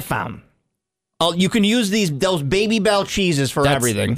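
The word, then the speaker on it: Babybel: This one is the Baby Bell.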